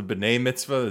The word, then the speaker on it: B'nai Mitzvah: which is weird because it doesn't rhyme with like bat mitzvah, you know what I mean? It rhymes with b'nai mitzvah.